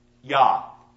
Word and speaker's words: Yah: And at the very end of the word for flame in Hebrew is an unusual addition that we would pronounce Yah. Yah. Not Yahweh.